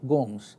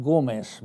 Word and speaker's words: Gomes: And then she stopped and she said, there is one man in New York whose name is Michael Gomes. I'm afraid she mispronounced it Gomez, because that's our California Spanish way of putting it.